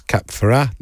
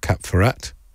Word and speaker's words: Ferrat: Andrew says it's Cap Ferrat, not Cap Ferrat.